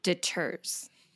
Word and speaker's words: deters: It also detours. Did I say that right? Detours people from booking with. – Deters. – Deters. Yeah. Deters people from booking with you.